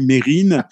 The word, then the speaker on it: Mérine: moi je m'appelle Mérine, c'est comme ça que je prononce mon nom. Je comprends pas pourquoi on le prononcerait différemment.